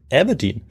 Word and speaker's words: Aberdeen: Der äh, lässt daraufhin nicht lange auf sich warten und führt seine Truppen unverzüglich in den Norden nach Aberdeen, an die Ostküste Schottlands. Und das mhm. habe ich deshalb so schön ausgesprochen, weil du die Stadt ja auch gut kennst. Ja, du hast es auch richtig ausgesprochen.